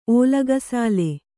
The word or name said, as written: ಓಲಗಸಾಲೆ